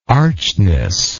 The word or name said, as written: archness